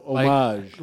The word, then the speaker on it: homage: The H is silent. – Oh, homage.